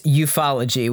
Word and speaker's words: ufology: It should be pronounced ufology, which is worse.